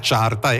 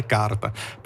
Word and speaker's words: charta: Solo che la carta contiene un H, ma è latino e non si legge, no? Non si legge quell'H lì, non è charta, è carta.